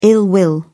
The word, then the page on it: ill will: British English